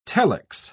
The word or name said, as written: telex